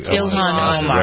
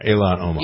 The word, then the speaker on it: Ilhan Omar: At first, you know, the attention that's being given to AOC and to the Muslim lady whose name I can never pronounce. Help me. – Ilhan Omar. Right. Yeah. – Omar. Ilhan Omar.